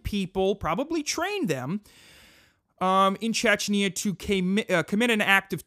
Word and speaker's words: Chechnya: So basically they said, if we release these records involving our connections with the apartment bombings in Russia and our pitter-pattering in Chechnya, is that That's how you pronounce that country? Chechnya? What is it? I don't even know what it is. Let's see. Chechnya, yeah. They said,